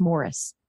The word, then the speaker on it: Maurice: Might be Morris. I think it's spelled Maurice, but it's Morris.